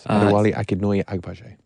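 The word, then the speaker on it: Adewale Akinnuoye-Agbaje: Adewale Akinnoye Agbaje.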